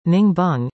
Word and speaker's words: Ninh Binh: Ninh Binh, pronounced Ning Bung,